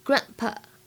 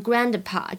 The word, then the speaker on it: grandpa: 在这段对话当中呢，首先我们看一下第一个单词 grandpa。 grandpa 它当中明显有一个完全失去爆破，所以大家不要读成 grandpa，这样真的很难听。